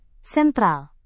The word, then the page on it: sentral: indonesian